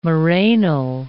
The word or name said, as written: morainal